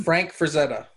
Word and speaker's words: Frank Frazetta: Like, he painted Metal Shark Bro in, like, the Conan-style Fran Freshetta. Is that how you pronounce his name? – Frank Frazetta. – Frank Frazetta, like, in the Frank Frazetta-style, like, Conan painting.